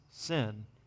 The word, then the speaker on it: sin: So in Hebrew, if the dot's on the very beginning, which is right to left, it's shin. If it's on the end, it's sin.